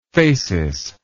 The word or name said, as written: phasis